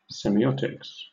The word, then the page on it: semiotics: British English